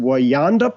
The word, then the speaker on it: Wayanda: And then in Queens, I believe it's pronounced Wayanda Park.